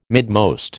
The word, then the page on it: midmost: American English